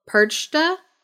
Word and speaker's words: Perchta: So there's this other woman called Perchta. I think I'm saying that right. Probably not because it's German. It's P-E-R-C-H-T-A.